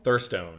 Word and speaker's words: Thurstone: Thurstone. Thurstone. Yeah, it's spelled Thurstone, but pronounced Thurston.